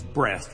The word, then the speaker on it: Brest: That's right, their name was Breast, but it's not what you're thinking, okay? It's spelled B-R-E-S-T, which is a town in France, which is where their families came from.